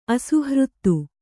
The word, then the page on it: ಅಸುಹೃತ್ತು: kannada